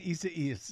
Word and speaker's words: Isaias: Hurricane, how do I say this, Desi Doyen? – Isaias. – I see what you're doing. You're laughing at me. Hurricane Isaias. – There you go.